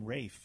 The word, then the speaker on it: Ralph: it's also a Welsh pronunciation is the word name Rafe.